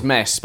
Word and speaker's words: Metz: Because we've got Metz and Mess. And it is Metz.